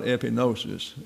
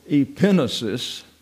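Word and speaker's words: epignosis: So people sort of question it's not epinosis.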